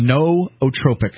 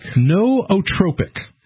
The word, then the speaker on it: nootropic: Nootropic. – Nootropic.